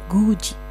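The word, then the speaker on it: guji (굳이): Jadilah tulisannya good i atau gudi tapi pelafalannya menjadi guji. Ya, hati-hati ya. Diget plus suku kata i itu menjadi ji. Oke.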